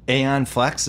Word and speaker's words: Aeon Flux: Aeon Flux, is that how you pronounce it? – Yeah, that was the one.